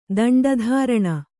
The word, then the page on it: ದಂಡಧಾರಣ: kannada